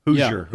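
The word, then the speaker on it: Hosier: Those who heard Hoosier, am I saying that right? – Hoosier. – Yeah.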